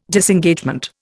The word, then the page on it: Disengagement: Indian